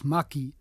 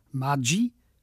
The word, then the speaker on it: Maggi: Der Menschenfreund Julius Maggi. Oder italienisch ausgesprochen Maggi, schweizerisch Maggi, stammt aus einer Familie von raffinierten lombardischen Feinschmeckern und von soliden schweizerischen Kaufleuten.